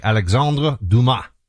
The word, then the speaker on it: Alexandre Dumas: But it was actually The Three Musketeers by Alexandre Dumas.